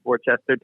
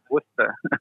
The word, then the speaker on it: Worcester: First time I went there, I thought it was Worcester too. I'm immediately corrected, and I know it's Worcester.